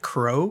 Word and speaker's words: Clow: It's the clow, but it's pronounced crow. Like his name is Kuro Rido. Like it's just clo.